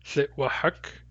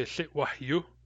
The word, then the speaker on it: thitwahak: Thitwahak. In a Hadasa dialect, we don't say thitwahak, we say thitwahyu. Thitwahyu. Same root, different ending. In Barkley and Central dialects, we say Thitwahak.